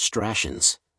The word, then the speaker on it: Stratians: Included among Montserratians, or Stratians, pronounced Stratians, as we call ourselves,